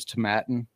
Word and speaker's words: Tomatin: I was told I butchered the name, but it was Tomatin.